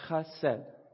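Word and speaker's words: chesed (חסד): And it's actually pronounced chesed in the Hebrew language.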